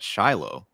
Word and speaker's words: Shiloh: And I know you also were the director of excavation at Shiloh.